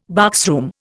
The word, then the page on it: boxroom: Indian